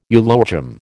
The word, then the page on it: eulogium: American English